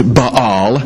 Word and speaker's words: Baal: The god of that land was named Baal, or you might hear it pronounced Baal, B-A-A-L, a god other than the god of Israel.